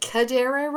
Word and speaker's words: cadere: Whereas the C-E-D, we have like intercede or concede and stuff. – Uh-huh. – That comes from is it cadere, Ross, to move? Is that how it's pronounced? – Yeah. – It's C-E-D-E-R-E.